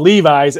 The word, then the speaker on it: Levis: First of all, if I'm wearing a pants called Levi's and I have to call you Levis, it's spelled the same as a big check mark against you for if I was an owner on that.